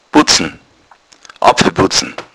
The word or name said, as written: butzen